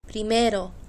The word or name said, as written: primero